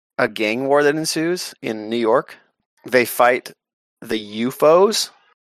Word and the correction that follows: U-Foes: The UFOs? – U-foes. F-O-E.